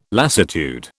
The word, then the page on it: lassitude: British English